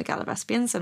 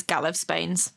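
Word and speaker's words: Gallivespians: So Galavespians, I only know how to say that, partly because of the interview with Jane Tranter where she mentions them and she calls them the Galavespians. And I was like, oh, that's how you say it then. Partly because of the audiobooks. Reading them, these books as a child, I read it as Galavespains the whole time.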